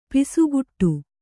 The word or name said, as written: ಪಸಗೆಡು